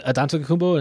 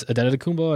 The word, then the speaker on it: Antetokounmpo: I said Antetokounmpo, and it's Antetokounmpo. I don't even know why I said that wrong the first time, but I've really been practicing this, given I have to do this announcing thing.